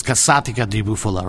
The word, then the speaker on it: Casatica di Bufala: I pronounce it Casatica. But then people come and tell me it's Casatica. It's not, right? I can stand by that when people come up to my counter and tell me I'm not pronouncing it right. So it's Casatica di Bufala, right? – Yeah.